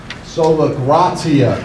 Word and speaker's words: Sola gratia: Sola gratia is how you say it.